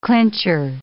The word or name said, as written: clincher